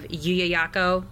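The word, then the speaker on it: Llullaillaco: They were found in 1999, so I'm sure that if anybody looks for the children of Yuyayako, it's spelt with L's because it's Southern America, it's from South America, you can find pictures of them.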